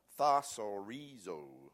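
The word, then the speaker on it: Thasorizo (θησαυρίζω): Thasorizo is the way you pronounce it.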